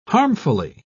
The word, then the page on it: harmfully: British English